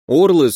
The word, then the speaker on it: орлы: Орлы с ударением на О — это вроде как местная валюта, распространенная по всему континенту.